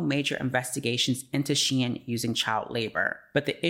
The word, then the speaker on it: Shein: Shein, pronounced Shein,